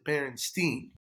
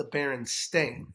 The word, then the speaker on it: Berenstain: And so I always pronounced it Berenstein. – Um, but it's the Bearstein Bears? – Yeah, but apparently it's the Berenstain, S T A I N.